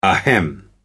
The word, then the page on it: ahem: American English